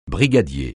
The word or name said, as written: brigadier